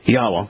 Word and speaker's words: Yahweh: Yahweh is the proper pronunciation of God's name.